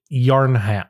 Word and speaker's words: Yharnam: Y h a r n a m. Yarn. Yharnam. – Yharnam. – Yharnam. It's like Putnam.